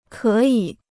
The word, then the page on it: 可以: kě yǐ